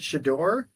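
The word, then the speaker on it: Shedeur: Do you pronounce it Shador? Sanders? – Yes.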